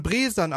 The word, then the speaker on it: Bresan: Bresan. Ach, die spricht man Bresan aus.